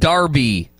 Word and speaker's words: Derby: Texas says it's spelled Derby but pronounced Darby.